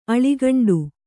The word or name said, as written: ಆಳುಗುಂಡಿ